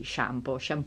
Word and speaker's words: shampoo: shampoo. Noi diciamo shampoo e ovviamente invece gli inglesi pronunciano quelle due O come una U.